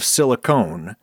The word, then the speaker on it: silicone: The difference is the silent E at the end of silicone that tells you to make a long O sound instead of a short one.